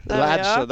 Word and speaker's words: Lanson: Let me just double check how you pronounce it. Lanson. – Lanson, that's the one.